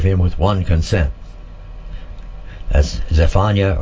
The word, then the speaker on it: Zephaniah: Then Zephaniah, or Zep, it says here, Z-E-P, or Zephaniah is actually the correct spelling and pronunciation.